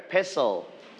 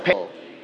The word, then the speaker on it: pestle: This one we call together pestle. – Pestle. – All right, so T, it's silent. T, it's silent. We don't pronounce T. Okay, this one you can say pestle.